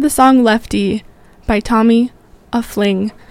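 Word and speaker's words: Oeffling: This first set is going to include songs by Tyzo Bloom, Hater, and Tommy Uffling.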